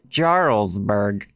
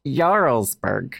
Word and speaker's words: Jarlsberg: You may initially think it's pronounced Jarlsberg, but it's Jarlsberg.